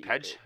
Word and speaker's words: Page: The P-A-G-E, not P-A-I-G-E. – Pedge? – Pedge.